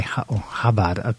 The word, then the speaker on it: Chabad: Entrepreneur Elon Musk joins Chabad Rabbi Chabad, people have been telling me it should be pronounced, Menachem Margolin, who chairs the European Jewish Association.